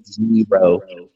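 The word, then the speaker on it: gyro: and who pronounced Euro, Yiro. That, you know, I needed to be corrected on that. I was not saying it right. So I want to throw that out there. So if you come to see us, just be like, hey, you don't say it right. It's Yiro.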